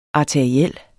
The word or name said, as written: arteriel